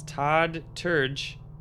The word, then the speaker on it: Terje: let me see if I can pronounce this. Todd Terje, T-E-R-J-E, is the artist.